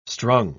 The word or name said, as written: strung